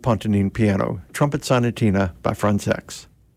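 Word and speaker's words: Françaix: Next, Trumpet Sonatina by Jean Frantzex. And that's the way he pronounced his last name. He thought Americans were actually kind of funny about their qualms about that pronunciation, but he would pronounce it Frantzex.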